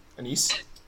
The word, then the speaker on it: anise: Pronounced anus. – Anise? – No, it's not. It's not anus.